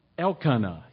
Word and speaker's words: Elkanah: and Hannah and Elkanah. Who, did I say that right? I think I did. Elkanah, yes.